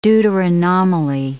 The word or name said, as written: deuteranomaly